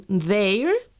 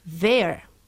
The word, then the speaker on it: their: Y lo mismo ocurre con their, posesivo, y la forma contraída de they are, que sería their.